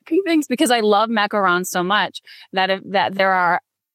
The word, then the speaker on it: macarons: So you had something in your about me about, I'm going to say it wrong, Macrons? – Oh, macarons. Yep.